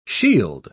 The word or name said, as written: shield